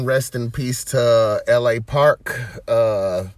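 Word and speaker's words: L.A. Park: The Park way they so pronounce it, I believe, is LA Park, is how I hear the announcer say it.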